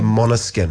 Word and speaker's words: Måneskin: It's spelled M-A, but it's got a little... – Dots. – Mm. Yeah, dots above it, which changes it to a moth sound, does it? So they're Måneskin.